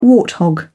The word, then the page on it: warthog: British English